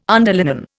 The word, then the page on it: underlinen: Indian